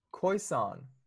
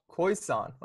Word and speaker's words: Khoisan: Let me see how it. Koisan. – Koisan. – Koisan. Okay.